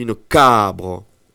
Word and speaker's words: cabre: Par exemple, on dit une cabre. J'ai accentué le A. C'est l'avant-dernière syllabe.